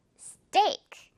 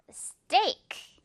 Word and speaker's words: steak: 선생님도 좋아하는데, 바로 우리가 좋아하는 steak. 역시 한국식 발음으로는 스테이크이지만 스테이크 하면은 원어민들은 좀 영어 원어민들은 귀가 살짝 불편할 수가 있어요. 왜냐? 지금 이거 또 역시 진한 우정 있으니까 스테이크.